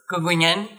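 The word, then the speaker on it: Gungunhana: o Gugunhana, ou como pronuncia... Gugunhane, é, que, que, enfim, no final do século XIX foi é, destronado, digamos assim, pelos portugueses e enviado para o exílio.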